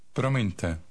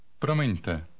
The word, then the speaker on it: Promiňte: Promiňte. Promiňte.